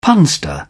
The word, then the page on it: punster: British English